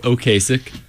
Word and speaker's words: Ocasek: Rick Ocasek.